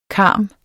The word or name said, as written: karm